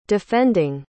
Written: defending

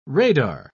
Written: radar

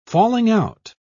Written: falling-out

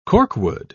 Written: corkwood

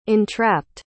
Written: entrapped

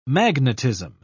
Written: magnetism

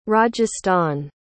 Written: rajasthan

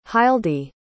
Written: hildie

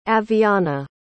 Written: aviana

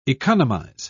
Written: economize

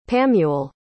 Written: pamuel

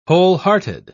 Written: wholehearted

